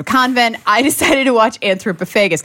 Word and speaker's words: Anthropophagus: It's got like an extra syllable in there that I tend to leave out. Anthropophagus? – Because it's pop and then haggis.